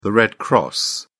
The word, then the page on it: red cross: British English